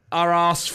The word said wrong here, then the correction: Aarhus: Yeah, Aarhus.